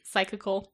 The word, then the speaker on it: psychical: Cyclical? – Like, psychical. – What's it spelled like? – Oh, psychic? – Psych. Like psych. Yeah. – Oh, I was thinking like cyclical. – No. Yeah, like a bicycle. – Like with a P.